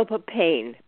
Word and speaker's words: papain: Bromelain, and then later on I heard about the papaya, which is papain, I want to say. – Oh, papain. Yes. – Papain. My pronunciation was off, but I'm understanding that there are several different kinds of enzymes.